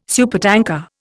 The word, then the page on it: supertanker: Indian